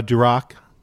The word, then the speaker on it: Duroc: And also Duroc, right? Am I saying it right, on the pork? – Yep, Duroc pork, yep.